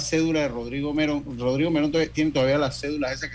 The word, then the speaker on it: Merrón: La gente le dice Merón, pero legalmente se escribe Merrón, M-E-R-R-O-N. Pero yo una vez en una entrevista lo puse como Rodrigo Merrón que subí hace algunos años en las redes sociales. Eh, y entonces me comenzaron a corregir, ¿no? que no era Merrón, sino que era Merón.